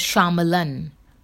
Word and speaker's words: Shyamalan: Night Shyamalan's. I don't know why you'll call him Shyamalan. That's how you'll pronounce it. But the correct Indian pronunciation is Shyamalan.